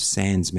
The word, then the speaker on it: Sanz: It's under my project name of Sanz Mantra, which is spelled S A N Z.